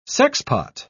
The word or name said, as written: sexpot